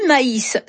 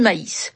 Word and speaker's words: maïs: Du maïs, ça s'écrit M-A-I avec un tréma et S. Sans le tréma, ça donne le mot mais, la conjonction de coordination. Avec le tréma, c'est du maïs.